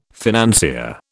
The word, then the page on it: financier: British English